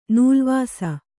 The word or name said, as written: ನೆಲವಾಸು